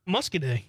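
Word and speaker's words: Muscoda: Gary from Madison says the town in southwest Wisconsin is pronounced Muscaday.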